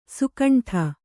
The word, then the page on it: ಸುಕಂಠ: sukaṇṭha